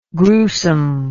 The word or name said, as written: grewsome